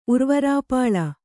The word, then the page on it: ಉರ್ವರಾಪಾಳ: kannada